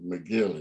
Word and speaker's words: McGill: And not so for Bobby, who I think he might have been sent to McGill, it's pronounced, by the way.